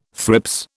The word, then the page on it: thrips: British English